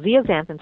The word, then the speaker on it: zeaxanthin: I was pronouncing it incorrectly for ages. It's called zeaxanthin, starts with a Z.